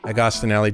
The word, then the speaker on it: Agostinelli: And we're joined by a new co-host, MD PhD student LJ Agostinelli.